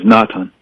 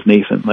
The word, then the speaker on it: Natan: His name is Natan. – Can you spell it for me? – Nathan is the Hebrew word. – Nathan? – N-A-T-A-N in Hebrew, and the English is Nathan, as in the prophet.